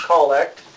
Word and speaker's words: collect: It's spelled like collect, but pronounced collect.